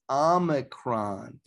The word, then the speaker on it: Omicron: So you're saying I should use my Omnicron on that. Right, Mike. First of all, you need to pronounce it correctly. It's not Omnicron. It's Omicron. There's no N in there.